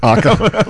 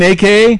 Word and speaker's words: Aka: I'm like, you mean AKA? He's like, AKA. It's spelled AKA, but it's Aka.